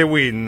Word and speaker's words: wind: Però vento si dice win, no, invece mi hanno sempre corretto.